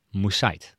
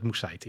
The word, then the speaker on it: Moussaid: Wij hebben dat in een eerdere aflevering ten onrechte uitgesproken als Moussaid. En toen heeft een lezer boos gereageerd. Het is Moussaid. – Kennelijk, ja. Ja, in Hilversum wordt het ook vaak anders uitgesproken, maar kennelijk is het Moussaid inderdaad.